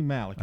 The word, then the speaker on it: Malek: And 1988, Rami Malek. Is that how you say it, or Malek? Rami Malek. Rami Malek, okay.